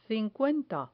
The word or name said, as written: cincuenta